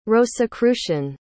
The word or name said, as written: rosicrucian